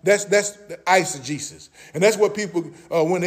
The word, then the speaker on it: eisegesis: Now, the opposition word, mother, I know you've been waiting on this, is called eisegesis. Somebody say eisegesis. Okay, eisegesis is spelled E-I-S-E-G-E-S-I-S. Eisegesis. E-I-S-E-G-E-S-I-S.